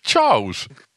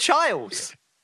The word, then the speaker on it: Charles: Charles. – Yeah, Charles. You said Chiles. – Did I? – Yeah, you went Prince Charles.